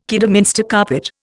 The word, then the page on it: Kidderminster carpet: Indian